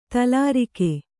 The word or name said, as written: ತಲಾರಿಕೆ